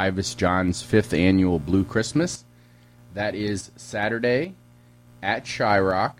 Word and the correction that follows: Shryock: I always say it wrong. – Shryock.